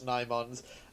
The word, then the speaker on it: Nimons: What about the Nymons or the Nimons or the Nymons? – Yeah, it's Nymons. You're correct, it's Nymons.